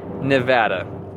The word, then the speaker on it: Nevada: Nevada. As someone who's lived there, I can tell you that the proper pronunciation is Nevada. However, I do know people there that pronounce it Nevada.